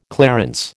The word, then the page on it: clarence: American English